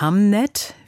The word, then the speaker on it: Hamnet: Katja, vielleicht sollten wir uns erstmal der Aussprache widmen. Der Film heißt Hamnet, wie der Sohn von William Shakespeare, und nicht Hamlet, wie das Drama von Shakespeare.